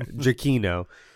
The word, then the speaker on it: Giacchino: as we learned. – Yeah, pronounced. – Yeah, yeah. Giacchino.